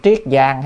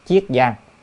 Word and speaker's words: Chiết Giang: Người ta nghe nói ở tỉnh Triết Giang hay là Chiết Giang á, có người đọc là Triết Giang, người đọc là Chiết Giang,